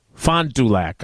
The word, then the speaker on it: Fond du Lac: There's no J in Fond Thank you.